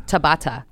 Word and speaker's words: Tabata: Tabata. T-A-B-A-T-A.